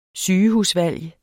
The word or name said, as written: sygehusvalg